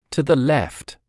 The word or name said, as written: to the left